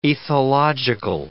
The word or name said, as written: ethological